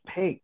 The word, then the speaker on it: Peek: The oldest record that I have access to back in the 1700s was originally spelled P-E-E-C-K-E. And if you were from Holland, it would have been pronounced Peek. And so to a census taker, when somebody says Peek, they probably said Peek and spelled it P-E-E-K.